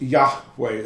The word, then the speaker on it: Yahweh: Because it's Yahweh. That's the pronunciation.